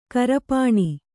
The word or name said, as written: ಕರಪಾಣಿ